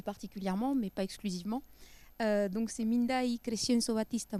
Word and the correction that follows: Bastida: Muñoz, lo he pronunciado bien, espero. Bastida, perdón.